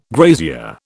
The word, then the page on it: grazier: British English